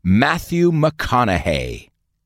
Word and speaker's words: McConaughey: Hay muchos guiris que no saben pronunciarlo bien tampoco. Se dice Matthew McConaughey.